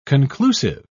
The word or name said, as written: conclusive